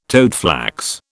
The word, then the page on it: toadflax: British English